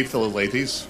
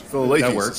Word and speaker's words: Philalethes: Is it Philalethes Society? – It can be Philalethes. – Philalethes Society.